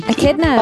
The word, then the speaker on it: echidnas: Echidnas? – Echidnas, oh.